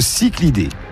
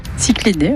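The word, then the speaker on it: cichlidé: Alors, le cyclidé. Ça s'écrit C-I-C-H-L-I-D, donc le. Ou le cyclidé. – Cyclidé, oui.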